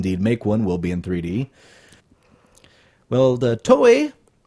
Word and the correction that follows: Toei: T-O-E-I, is that how you pronounce that? – Toei.